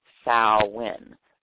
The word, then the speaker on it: Samhain: And the reason Samhain is the festival of the dead, meaning summer's end, and pronounced Samhain, like S-O-W-E-N, or S-A-A-H-W-I-N, but it's really spelled S-A-M-H-A-I-N.